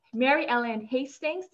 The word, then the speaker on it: Mary Ellen Hastings: Her name is Mary Ellen Hastings. Did I say it correctly? – Yes. – Awesome, awesome. Mary Ellen Hastings.